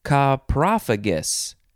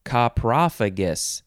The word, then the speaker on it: coprophagous: How do you say this one? Coprophagus. Coprophagus. C-O-P-R-O-P-H-A-G-O-U-S. Coprophagus. Adjective from 1826.